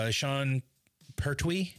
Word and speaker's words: Sean Pertwee: Well, I mean, you already brought up Sean Pertwee.